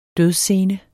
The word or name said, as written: dødsscene